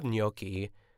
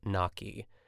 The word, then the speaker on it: gnocchi: I always said gnocchi. I would never say gnocchi, even though that's like the correct way to say it. Like my parents taught me that, and then I grew up, and then, you know, studied opera in college, so obviously the GN makes a gnocchi as opposed to like gnocchi is how I pronounced it.